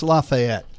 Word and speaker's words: Lafayette: It's pronounced Lafayette. It's the Marquis de Lafayette. He fought with Washington. He's a big part of the whole revolutionary thing.